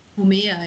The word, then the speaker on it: Homer: Und das ist ja auch so ein sehr episches Werk, das von Homer, wird das so ausgesprochen? – Homer, ja.